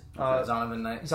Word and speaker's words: Zonovan Knight: But they did elevate Zavian Knight. – Zonovan Knight. – Excuse me if I mispronounced your name, bro.